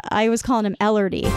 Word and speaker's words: Elordi: It's pronounced Elordi, like, oh Lordy.